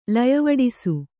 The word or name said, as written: ಲಯವಡಿಸು